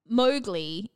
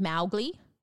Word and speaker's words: Mowgli: So, like, he's – Mowgli in the movie is what they say, but it's actually meant to be like Mowgli. – Oh. – Like it's Mao rhyming with cow.